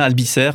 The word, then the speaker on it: Albisser: Ouais, c'était le thème de la, conférence hein, de, d'Éric Achille Albisser. Je ne sais pas si je prononce bien Albisser. Mais... C'est ça, c'est ça, comme ça qu'il faut le dire.